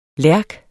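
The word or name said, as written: lærk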